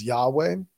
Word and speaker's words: Yahweh: It typically gets pronounced as Yahweh.